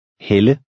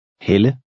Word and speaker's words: Helle: The first word makes a long E sound, and the second, a woman's name, makes the Danish short E sound. Listen and repeat number 10. – Hel, helle. Hel, helle.